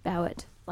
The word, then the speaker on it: Bowett: Anna Bowett. Yeah, whatever. – Bauer. – Bowett. – Like Bowett.